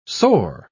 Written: soar

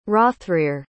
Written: hrothrehr

